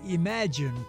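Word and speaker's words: EMAG!NE: And on today's podcast, he'll be talking about the Imagine program. Now, if it sounds like that I'm pronouncing that a little peculiarly, that is E-M-A-G, exclamation point N-E.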